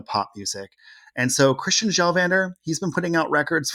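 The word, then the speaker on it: Kjellvander: Okay, this one is Christian Gjelvander, and it's with a silent K.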